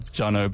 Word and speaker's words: Joneaux: I didn't do myself any favors with the spelling of my name, but it's Joneaux, and yeah, you just pronounce it Jono.